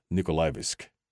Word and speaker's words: Nikolaevsk: He was correcting my pronunciation. Although I can talk like Russian, I cannot pronounce like Russian. Nikolaevsk.